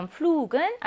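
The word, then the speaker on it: Flug: 그럼 flug은 알파벳 G가 어디에 있어요? 단어 음절 끝에 있으니까 본래의 발음이 아니라 크 발음을 내는 경우이네요.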